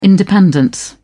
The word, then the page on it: independence: British English